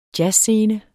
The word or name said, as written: jazzscene